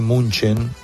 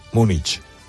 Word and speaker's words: Múnich: Es decir, si de la misma manera que no se dice München y se dice Múnich. Hay gente que cree que Múnich es la forma correcta en alemán y dice Múnich. Y no es así, es Múnich, porque es en español, Múnich.